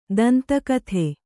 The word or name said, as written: ದಂತಕಥೆ